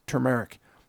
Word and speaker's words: turmeric: But the correct pronunciation is, in my opinion, turmeric.